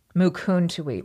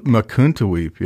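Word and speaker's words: Mukuntuweap: I'm not sure you pronounced that right. You said Makuntuweap. Is it Makuntuweap? – Makuntuweap. – Makuntuweap, yeah.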